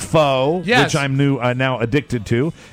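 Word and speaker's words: phở: It's pho. I'm pronouncing it pho. That's the way it's going to go.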